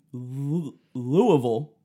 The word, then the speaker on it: Louisville: Well, yeah, so in Louisville, I'm trying to like say that.